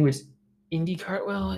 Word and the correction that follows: Indy Hartwell: Am I saying her name right? Yeah, Indy Hartwell, I believe Indy Hartwell.